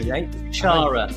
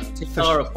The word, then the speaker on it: Thushara: Thushara. Thushara Polpitiye.